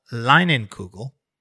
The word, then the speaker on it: Leinenkugel: This next story comes from a brand called Leinenkugel.